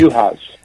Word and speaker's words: Juhasz: It's Juhasz? – Yeah. – Okay. – Juhasz.